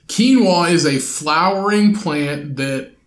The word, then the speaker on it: quinoa: Quinoa, pronounced quinoa, has recently received...